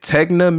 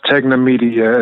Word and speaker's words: TEGNA: is it TEGNA Media or TGNA Media? What is that? – It's pronounced TEGNA Media.